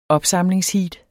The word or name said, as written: opsamlingsheat